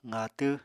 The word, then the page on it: qaatw: apatani